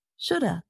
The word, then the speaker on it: shoulda: But native speakers usually shorten these with contractions, so it sounds like this. Should have, could have, would have. – And sometimes native speakers reduce or shorten the pronunciation even more. So it sounds like this. Shoulda, coulda, woulda.